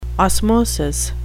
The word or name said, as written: osmosis